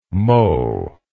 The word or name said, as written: Moe